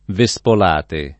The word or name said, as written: Vespolate